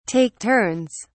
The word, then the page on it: take turns: British English